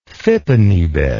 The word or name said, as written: fippenny bit